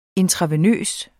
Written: intravenøs

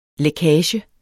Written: lækage

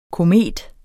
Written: komet

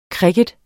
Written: kricket

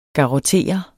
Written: GARROTTERE

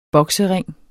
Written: boksering

